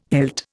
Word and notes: hilt: Indian

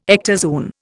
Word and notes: ectozoon: Indian

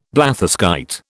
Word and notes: blatherskite: British English

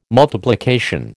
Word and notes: multiplication: American English

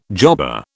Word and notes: jobber: British English

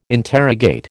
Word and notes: interrogate: American English

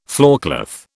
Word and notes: floorcloth: British English

